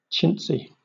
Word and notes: chintzy: British English

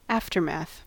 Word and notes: aftermath: American English

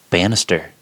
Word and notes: banister: American English

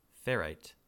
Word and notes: ferrite: American English

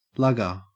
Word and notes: lugger: Australian English

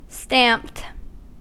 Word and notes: stamped: American English

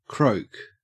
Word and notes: croak: Australian English

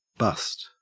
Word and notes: bust: Australian English

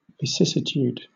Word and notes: vicissitude: British English

